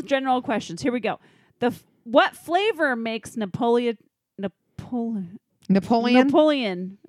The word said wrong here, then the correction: Neapolitan: Is that right? Am I saying that right? – Napoleon. – Neapolitan. – Neapolitan.